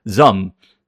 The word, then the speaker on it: ZA/UM: That ZA/UM is pronounced ZUM. It's just one like syllable, ZUM. It's like ZUM. It's not ZAM. It's not ZUM, but kind of like ZUM. But yeah, one syllable.